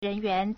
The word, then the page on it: 人员: 人員; rényuán